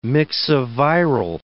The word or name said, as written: myxoviral